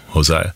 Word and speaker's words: Josiah: How do you say J-O-S-I-A? It's not like Jose, you know, Josiah.